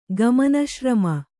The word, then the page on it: ಗಮನಶ್ರಮ: kannada